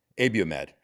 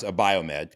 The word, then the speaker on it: Abiomed: So Johnson & Johnson bought Abiomed, because it's not pronounced a Biomed, because I got corrected on that. It's Abiomed.